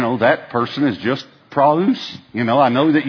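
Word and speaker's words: praus (πραΰς): It is the word praus.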